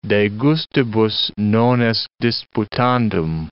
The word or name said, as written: de gustibus non est disputandum